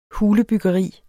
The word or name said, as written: hulebyggeri